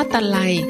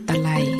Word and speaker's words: ตะไล: ตะไลสะกดด้วยตอเต่าสระไอไม้มาลายลอลิงอ่านว่าคำว่าตะไลสะกดด้วยตอเต่าสระอะไม้มาลายลอลิงอ่านว่าตะไล